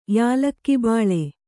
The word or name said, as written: ಯಾಲಕ್ಕಿಬಾಳೆ